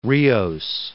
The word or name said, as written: Rios